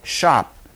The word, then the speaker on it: scop: You pronounce S-C-O-P, shop, and it's translated poet.